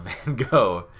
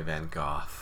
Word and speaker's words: Van Gogh: I reject the way the British say Van Gogh. So, the American way is Van Gogh. Van Gogh. And then the Dutch. When a Dutch person says it, or when an English person says it the quote unquote correct way, it sounds more like Van Gogh.